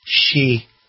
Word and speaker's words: Xi: He's a he, but his name is pronounced Xi.